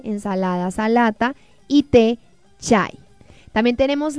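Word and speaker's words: salata (سلطة): También tenemos la palabra ensalada que se pronuncia salata.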